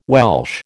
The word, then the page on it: Welsh: American English